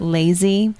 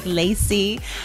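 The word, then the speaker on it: LAYSI: It's L-A-Y-S-I. Some people pronounce it lazy, and I tell them not to say it like that because that's what we're combating. – Yeah. – I really like to enunciate that S, right, the S. I'm like Lacey.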